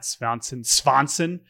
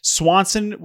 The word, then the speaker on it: Svanson: They get back a relief prospect named Matt Svanson. Swanson with the V is how Matt Svanson's name is spelled.